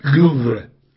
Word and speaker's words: Louvre: Sí, no, yo la mía es mala también, pero con que no pronuncien la E, basta. Louvre. Sin pronunciar la E.